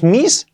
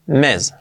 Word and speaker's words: miss: La pronunciación, cuidado que no es miss, es miss. La I como una E, miss.